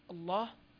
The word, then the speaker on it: الله: هنا أيها الكرام ننبه إلى مسألة أيضا ذكرها المؤلف في صفحة المعلم، وهي أن كلمة لفظ الجلالة الله، هذه الكلمة تكتب بدون ألف بعد اللام المشددة نظرا لكثرة استعمالها، ولكن عند النطق بها تثبت هذه الألف، كذلك عند تهجئ الطالب والطالبة لها يثبتها بعد اللام فيتهجأ هكذا الله الله.